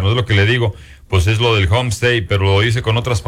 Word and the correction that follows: homestead: Homestead, not homestate. – Bueno, pues homestead. ¿Cómo se dice? – Homestead. – ¿Y cómo dije? – Homestate. – ¿Y cómo se dice? – Otra vez. – Vamos a aprender. – Dígalo correcto, no es homestay.